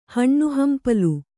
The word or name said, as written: ಹಣ್ಣುಹಂಪಲು